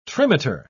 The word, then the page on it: trimeter: British English